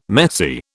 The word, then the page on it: messy: British English